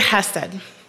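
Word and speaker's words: hesed (חסד): The word for love there is that Hebrew word chesed, I'm not going to do the whole thing for us today, but that's how it should be pronounced. It's the word hesed.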